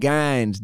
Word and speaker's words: Gines: I'm back here with my little brother, Christian Gines.